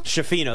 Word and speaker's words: Schifino: Schifino, there you go.